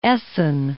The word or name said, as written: Essen